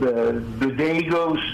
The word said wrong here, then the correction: Bodegas: Voce Bodegos Manzanos. Bodegas, B-O-D-E-G-A-S, capital M-A-N-Z-A-N-O-S.